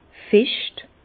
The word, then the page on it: fescht: Bas-Rhin; Herrlisheim